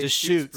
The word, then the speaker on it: Deschutes: I'm drinking Deschutes. Is that how you say it? – That is? – That's Deschutes.